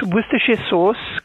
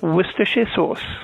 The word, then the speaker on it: Worcestershire sauce: So it's Worcestershire sauce, comes from the city of Worcester in the English Midlands, and the easiest way to remember how to pronounce it is to remember that the word starts the same as when you call someone a wuss.